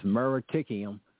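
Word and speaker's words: muriaticum: And the mineral for that is Kali, and I'm going to spell the other part to you, M-U-R-I-A-T-I-U-I-C-U-M. That's M-U-R-I-A-T-I-C-U-M. How you pronounce that? – Meritikium, but you just say it's called Kali.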